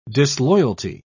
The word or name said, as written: disloyalty